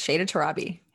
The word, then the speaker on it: Shayda Torabi: Shayda Torabi.